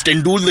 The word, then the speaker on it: Tendulkar: It's Tendul, the U is long actually.